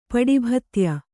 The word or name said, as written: ಪಡಿಭತ್ಯ